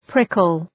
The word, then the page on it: prickle: ‘prıkəl